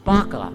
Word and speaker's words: Baca: Now, we say Baca. It's really, in the Hebrew, it's pronounced Baca. "They make it a place of springs.